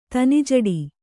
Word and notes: ತನಿಜಡಿ: kannada

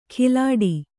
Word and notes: ಕಿಲಾಡಿ: kilāḍi

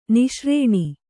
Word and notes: ನಿಶಾರಣ: niśaraṇa; Kannada

